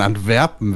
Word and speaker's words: Antwerpen: Aber warum denkst du zuerst an Antwerpen, wenn du an Urlaub denkst? – Weiß ich nicht.